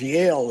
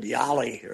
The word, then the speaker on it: Yale: Charles couldn't pronounce Yale the other day. When Yale beat Auburn, he said Yali or something. Yali, we got beat by Yali.